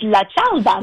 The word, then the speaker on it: cialda: No, it's la cialda.